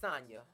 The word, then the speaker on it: Sanya: It's Sanya. – Yeah. – But her name's spelled like Tanya.